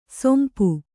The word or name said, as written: ಸೊಂಪು